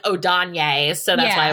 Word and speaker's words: Odayne: In the audiobook, they pronounced it Odane. – Okay. – How is it spelled? – O-D-A-Y-N-E.